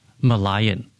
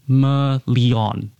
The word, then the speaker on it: Merlion: And then what I didn't realize was not everyone pronounced Merlion. – Yes, I can imagine. – The way we do. – Yes. – It actually came across as Merlion.